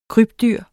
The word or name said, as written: krybdyr